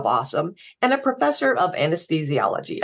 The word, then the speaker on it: AWSM: Um, awesome is the Center for the Advancement of Women in Science and Medicine, AWSM, which we pronounce Awesome.